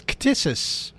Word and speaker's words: ktisis: Well, there's a word that is commonly used, and it's a hard one to pronounce. It almost sounds like you're trying to tickle somebody. It's ktisis.